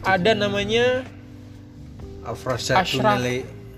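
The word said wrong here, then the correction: Avrasya Tüneli: Au, bacanya Aurasia Tuneli.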